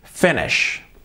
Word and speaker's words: finish: Y luego tenemos la SH del final. Ese sonido, nada de finish, finish, finish.